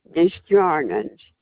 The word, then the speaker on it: Desjardins: Ava, I-V-A, Desjardins. – Thank you. – D-E-S-J-A-R-D-I-N-S.